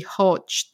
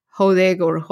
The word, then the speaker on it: Hodge: Anyway, so his last name is supposed to be Hodge. So it's H-O-D-G-E. – Yeah, like the regular Hodge, yeah. – Yeah, that is how you're supposed to pronounce it.